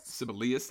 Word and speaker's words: Sibelius: I don't know how to say his name. I'm so sorry. – Sibelius, that sounds right.